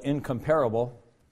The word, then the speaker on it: incomparable: You might want to spell it incomparable, but you have to put the syllable on the right, or the emphasis on the right syllable, and say incomparable,